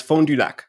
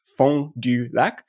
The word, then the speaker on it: Fond du Lac: So he lives in a city which is correctly pronounced Fond du Lac, right? It's Fond du Lac.